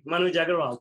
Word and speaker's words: Manuj Aggarwal: Manuj Aggarwal.